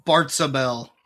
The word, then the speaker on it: Bartzabel: Which is and he caught upon a demon called a kind of like Beelzebub, but pronounced slightly differently. I'm not going to try to pronounce it right. – Bartzabel.